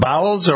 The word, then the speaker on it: Bowles: B-O-W-L-E-S. Am I pronouncing it Bowles or Bowles? – Bowles, yeah. No, put the E in the other place. Yeah, there we go.